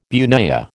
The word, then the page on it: bunya: American English